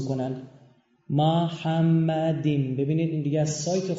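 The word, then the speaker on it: محمدیم (מחמדים): اما اینجا یه کلمه وجود داره که عبریش تلفظش اینه، ببینید، محمدیم.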